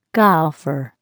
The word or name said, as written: golfer